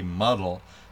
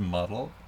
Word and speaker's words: MDL: Hughes clarifies that the Infocom documentary on Archive has them say muddle, so our intuition was correct. Foolsbane appends, it was indeed pronounced muddle, but just based on its name, MDL.